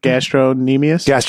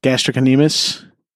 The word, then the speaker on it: gastrocnemius: Gastronemius? – Gastrocnemius.